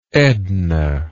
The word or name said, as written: edna